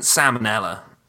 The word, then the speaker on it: Salmonella: How come it isn't salmonella? You really pronounce the L in that. Salmonella. – Salmonella. Salmonella. – Salmonella. But you don't call it a salmon.